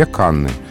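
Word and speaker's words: Cannes: Но на первом месте все-таки зафиксирован вариант во множественном числе Канны. Поэтому, если привычно его употреблять, то так и стоит. – Ну, потому что он по-французски «кан», Э, «с» на конце не читается.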